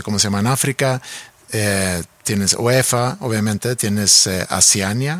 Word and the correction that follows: Oceanía: ¿o cómo se dice? – Oceanía. – Oceanía, sí.